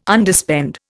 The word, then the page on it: Underspend: Indian